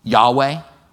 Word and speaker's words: Yahweh: And we think it's pronounced Yahweh. We've lost the vowels, but Hebrew scholars believe that's the way that it's pronounced.